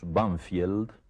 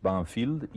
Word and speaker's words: Banfield: Sí, en uno de los suburbios de Buenos Aires, que nosotros pronunciamos Banfield, pero que tiene un nombre inglés, Banfield, y ese nombre inglés corresponde a uno de los muchos ingenieros ingleses que construyeron el sistema ferroviario argentino.